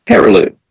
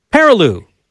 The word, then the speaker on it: Perreault: He has other writers helping him out, like, how do you pronounce Gary's last name? – Perreault. – Perreault. Good French name. Good French name, kind of like Perreault.